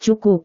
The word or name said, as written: cukup